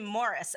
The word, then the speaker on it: Maurice: And actually, he pronounces his name Morris.